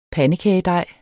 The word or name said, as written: pandekagedej